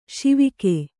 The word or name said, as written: ಶಿವಕ